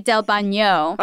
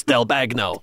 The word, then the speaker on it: Del Bagno: Also, the letters G-N-O are pronounced gno, so it would be Del Bagno. – Okay. Fair, yes. And I brought this up to my dad, and he said... Well, not in New Jersey, it's not. – Exactly. – Which is where my family was from. So in Jersey, it was Del Bagno. – Jersey. – You know? – The Jersey pronunciation. You say the G.